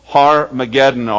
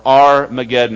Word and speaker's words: Armageddon: gathered these armies to a place which in Hebrew is called Har-Mageddon or Armageddon, depending on how you want to pronounce it.